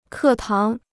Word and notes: 课堂: kè táng

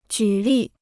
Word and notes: 举例: jǔ lì

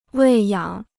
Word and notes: 喂养: wèi yǎng

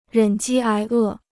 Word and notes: 忍饥挨饿: rěn jī ái è

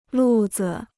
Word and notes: 路子: lù zi

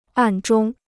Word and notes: 暗中: àn zhōng

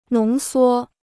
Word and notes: 浓缩: nóng suō

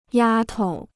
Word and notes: 丫头: yā tou

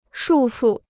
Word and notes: 束缚: shù fù

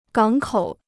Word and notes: 港口: gǎng kǒu

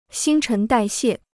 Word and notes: 新陈代谢: xīn chén dài xiè

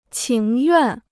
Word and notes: 情愿: qíng yuàn

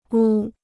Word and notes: 屋: wū

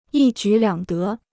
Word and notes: 一举两得: yī jǔ liǎng dé

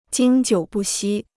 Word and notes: 经久不息: jīng jiǔ bù xī

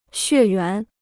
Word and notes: 血缘: xuè yuán

